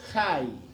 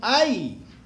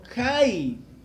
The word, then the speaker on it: Hai: Y cuando lea ahí el nombre de la ciudad, que dice H-A-I, ¿cierto? Pronúncielo Jai. Bueno, no hay, como si fuera en español, sino Jai, porque es una palabra hebrea. Y la H en hebreo suena J.